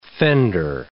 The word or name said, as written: fender